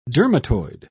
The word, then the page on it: dermatoid: British English